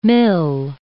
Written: mill

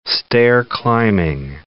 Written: stair-climbing

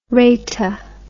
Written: raita

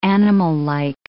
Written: animallike

